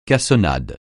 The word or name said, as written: cassonade